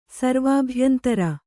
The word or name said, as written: ಸರ್ವಾಭ್ಯಂತರ